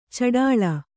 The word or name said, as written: ಛಢಾಳ